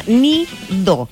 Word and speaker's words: Needoh: Ahora, este producto, como les dije yo anteriormente, se llama Nidoh. Esto es se escribe Needoh, que fabrica la marca estadounidense Schilling.